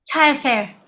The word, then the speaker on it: Tailleferre: Is it pronounced Taillefer? – Taillefer, yes.